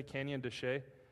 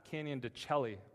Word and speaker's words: Canyon de Chelly: Anyone been to Canyon de Chelly? It's not Canyon de Chelly, which is what I thought it was, but it's like a silent L.